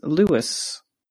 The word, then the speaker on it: Louis: In the last episode, we were talking about it, and I whenever I think of the Falcon, I think Louis because I've seen it written down now more than I've heard it spoken, but it is actually pronounced Lewis.